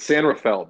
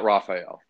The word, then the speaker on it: San Rafael: It's San Rafael, Ben. Get it straight. Not Raphael.